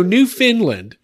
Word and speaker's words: Newfoundland: It was the finest kettle from Newfoundland, I say. I love Sorry, how did you say that? – Newfoundland. Newfoundland. Whatever. No, Newfoundland.